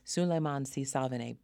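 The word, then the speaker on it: Souleymane Sy Savane: And the actor who plays Father Patrick, Suleiman C. Savane, am I mispronouncing his name? – That's correct, C. Savane. Suleiman C. Savane, born in Côte d'Ivoire, West Africa.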